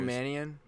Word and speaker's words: Mannion: Nico Mannion? – Mannion.